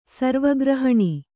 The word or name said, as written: ಸರ್ವಗ್ರಹಣಿ